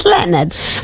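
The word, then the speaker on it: Lynyrd: If you listen carefully to me, I'll never say the artist's name because I don't know how to pronounce it because it's written like Linrid Skinner. He says it's Leonard. I'm like, I'm sorry if it's Leonard. It should be spelled as L-E-O-N-A-R-D. And on the system, it's spelled as L-Y-N. – Yeah. – Y-R-D, that's Linrid, and I don't know Linrid, so I'm like, I'm not saying the word Linrid on air.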